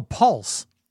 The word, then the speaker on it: pulse: Pause. Pulse. – Oh, Pulse. – That's what I said. – How did you say it again? – Pause. – Hold on, let's stop for a second here. – Pulse. – P-U. All right, say P-U-L-S-E. Pulse. – Paul. Say Paul. Say Paul. And Paul. No, not pa. Say Paul and pronounce the L.